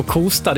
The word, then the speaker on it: Kosta: Och uttalet på Kosta, det satt som en smäck.